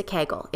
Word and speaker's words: Kegel: what is a Kegel? It rhymes with bagel.